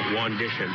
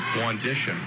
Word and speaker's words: Wandishin: Second free throw is no good, and the rebound comes down to Wandishin, who's in the ballgame. Please let me know if I'm pronouncing that wrong, because you would know. – Wandishin. – Wandishin, okay. Like an audition, only not.